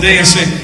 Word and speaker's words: dais: We have this slovenly crew of miscreants over here for a dais. A dais. I said it wrong. Thank you. Whatever. The dais. It seems fitting that we have these kind of guys over here.